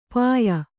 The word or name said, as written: ಪಾಯ